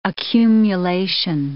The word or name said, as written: accumulation